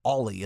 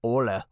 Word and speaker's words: Ole: but his name isn't Ollie. It's like Ole. But it looks like Ollie, kind of.